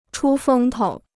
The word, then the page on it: 出风头: chū fēng tou